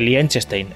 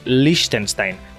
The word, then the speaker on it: Liechtenstein: Pues ya está, pero vamos, que lo importante no era el chiste este, lo importante era lo de lo de Liechtenstein, que no se dice Liechtenstein, es Liechtenstein.